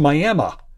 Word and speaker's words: Miami: The county seat of tiny Roberts County, Texas, is pronounced Miami.